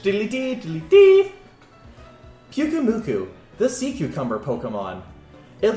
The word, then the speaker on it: Pyukumuku: How do you say that Pokemon's name? – Pyukumuku. – Pyukumuku.